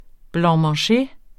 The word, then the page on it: blanc-manger: [ blɑ̃mɑ̃ˈɕe ]; [ blɑŋmɑŋˈɕe ]